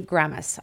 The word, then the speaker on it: Gamas: We have Steve Gamas.